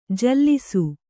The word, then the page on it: ಜಲಸ: jalasa